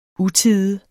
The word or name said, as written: utide